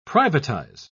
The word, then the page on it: privatise: British English